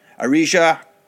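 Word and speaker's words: Arija: The movie stars Rob Schneider, William Forsythe, Eddie Griffin, and Arla Bareckis. I think I said that wrong. Arisha Bareckis.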